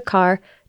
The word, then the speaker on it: Carr: Check in the show description so you can join it, or you can just go to my website, which is Trisha Carr Charm, and it's easy to find. My name is spelled T-R-I-C-I-A-C-A-R-R, and there's like a pop-up that'll help you to sign up for my mailing list.